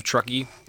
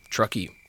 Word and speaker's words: Truckee: The Indian's name sounded like Troquet to the white man, who dubbed him Truckee.